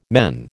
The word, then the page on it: men: American English